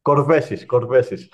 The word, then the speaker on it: Korvesis: Korvesis.